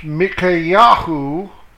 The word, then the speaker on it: Micaiahu: It's spelled differently than it is in the English. It's M-I-Y-C-H-A-H. And it's also pronounced Micaiahu.